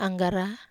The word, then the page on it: anggara: Wamesa